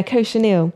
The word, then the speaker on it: cochineal: Yeah, cochineal.